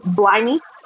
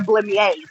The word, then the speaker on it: Blemmyae: I think people, Blemmy is the just Blemmy. – Blemmyae. – I'm gonna say the A is silent because my mouth won't function that way. So I feel like it sounds fancier if I say Blemmyae, like sparkly water.